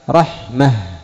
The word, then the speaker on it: رحمة: ماذا أبدلت التاء؟ أبدلت التاء هاء عند الوقف. أما في الخط يعني الكتابة تكتبها بنقطتين، رحمة في الكتابة تكتبها بنقطتين، وفي الوصل تصلها تنطقها تاء، لكن عند الوقف تقف عليها بالهاء تقول رحمة.